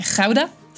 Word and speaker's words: Gouda: on prononce pas gouda mais rauda, j'en ai fait un dessin aussi.